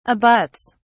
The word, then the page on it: abuts: British English